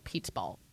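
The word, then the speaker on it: Pizzaballa: sounds like pizza ball. Pizza ball.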